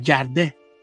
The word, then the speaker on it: Kardec: Pero, obviamente, como era francés, la, los franceses no pronuncian Kardec así con K. Se escuchaba más como si fuera Jardé.